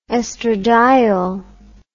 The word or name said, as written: oestradiol